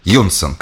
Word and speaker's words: юнсн: Вот так расшифровывается эта аббревиатура, но произносить она ее призывает в одно слово с ударением на первую «ю» – Юнсон.